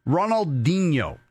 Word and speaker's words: Ronaldinho: Ronaldinho.